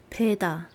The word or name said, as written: beada